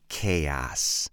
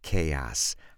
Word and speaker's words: chaos: Now remember, talking about pronunciation, by the way, remember how we spell chaos, okay? C-H-A-O-S. But when we talk about pronunciation, remember the trick for pronouncing a word properly. Two things. First, count the syllables. Count the number of syllables first. Chaos. Chaos. Okay. Chaos has two syllables.